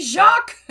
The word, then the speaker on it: Jacques: it's pronounced Jacques Tabernacle, and he went complete Quebecois, complete French, and I was just like, oh, I made him angry, and I had a good giggle over that. So he was like, it should be Jacques. And I'm like, listen, everyone has told us they pronounce it Jakes. We are just doing what we've been told.